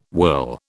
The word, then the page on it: whirl: British English